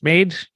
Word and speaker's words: MAiD: The acronym for this is pronounced MAiD, M-A-I-D.